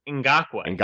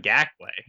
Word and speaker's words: Ngakoue: Yannick Ngakoue? What is it? – Ngakoue. – Ngakoue? – Not Ngakoue.